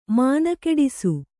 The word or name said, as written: ಮಣ್ಣುಗೂಡಿಸು